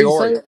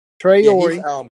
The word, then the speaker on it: Traore: But it looks to me like the leading receiver from what you can see right here would be, I'm going to pronounce his name wrong, Sado Traore. – Is that Traore. Yep. – Traore.